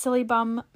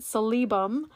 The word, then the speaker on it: Silybum: So it's usually pronounced Psyllium Marianum. So we have Psyllium, which is S-I-L-Y-B-U-M. Others pronounce it salibum.